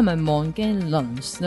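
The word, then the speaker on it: Morgellons: 应该是 Mongolians 呢、外国读音就是 Mongolians。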